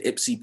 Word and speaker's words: Ypsi: He goes to Ipsy Prep.